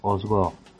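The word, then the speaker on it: Hossegor: Y escuchad, Juanma, que un oyente nos aclara cómo es la pronunciación del pueblo surfer. – Amén. – Queridos amigos, lo del surf en Francia es Osgo. Osgo o Candemore, como queráis.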